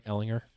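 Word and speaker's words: Ehlinger: Eleanor, I mean, Am I saying that right? Ellinger? – Yes. Okay, Sam Ellinger.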